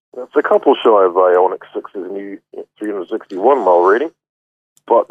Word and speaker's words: Ioniq: In fact, the new, I can never pronounce this one, the Ioniq? – Ioniq.